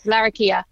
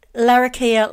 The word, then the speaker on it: Larrakia: So, listeners, she's from Darwin, from, how do you say that, Larrakia land? – Yes, Larrakia. – Larrakia land in Darwin, and that's in the Northern Territory, of course.